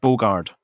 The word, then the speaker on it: Bogarde: Dirk Bogarde.